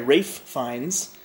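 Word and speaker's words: Ralph Fiennes: Kind of a specific period of time during the hotel's life where Rafe Fiennes. – There you go. – I just found out, apparently, is how you pronounce it. – Yep. But his brother's name is still pronounced Joseph. – Not Jose. Yeah. – It's Joseph. – Why not just call yourself Ralph? – Because his name is Rafe. – God damn it. – I think it's Welsh. – It is Welsh. – Yes!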